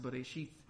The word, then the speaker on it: Bereshith: So this should be pronounced Bereshith and not Ith or anything like that.